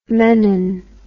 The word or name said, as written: menon